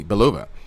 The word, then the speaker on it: Belova: According to Black Widow director Cate Shortland, Scarlett Johansson's Natasha Romanoff will pass the baton to co-star Florence Pugh's Yelena um, Belov, Belov, excuse me, Belova.